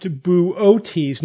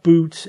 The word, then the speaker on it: Boötes: It's pronounced Boötes, not boots, and not Boötes.